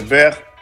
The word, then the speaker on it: beurre: en, en, en, francés manteca se dice beurre, que se escribe beurre.